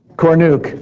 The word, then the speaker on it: Cornuke: Now, I don't know how many of you got to come when we had Bob Gornick come. And boy, I already got his name wrong, didn't I, Bill? Anyway, it's something like that, isn't it? Cornuke, thank you, Cornuke. Yes, there it is.